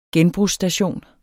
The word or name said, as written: genbrugsstation